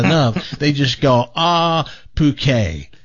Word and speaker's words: Phuket: Dan, it's actually a silent H and a long O. – It is? – Yeah, it's Phuket.